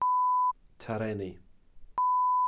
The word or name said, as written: tarreni